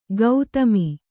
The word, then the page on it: ಗೌತಮಿ: gaugami